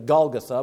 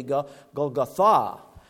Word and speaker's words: Golgotha: I looked it up, and the accent is really on the last syllable of this word. I'm used to saying Golgotha, but it's actually be Golgotha.